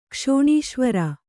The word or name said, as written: ಕ್ಷೋಣೀಶ್ವರ